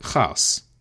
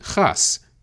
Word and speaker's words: gas: In English, we say chaos, but in Flemish, it sounds more like chaos. In Dutch and Flemish, the letter G is pronounced ch, and so van Helmont called it chaos, G-A-S.